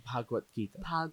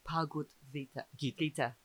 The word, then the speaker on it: Bhagavad Gita: Bhagavad Gita. – Bhagavad Gita.